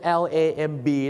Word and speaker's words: lamb: มันสะกดเป็น LAMB เนี่ยนะครับผม LAMB เนี่ยมันออกเสียงว่า lamb รู้จักพี่คนนี้ไหมครับพี่อ่างครับ